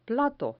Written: plato

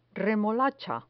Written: remolacha

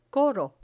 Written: coro

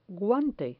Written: guante

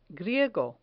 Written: griego

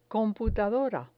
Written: computadora

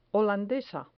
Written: holandesa

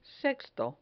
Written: sexto

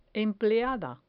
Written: empleada

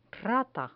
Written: rata

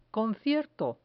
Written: concierto

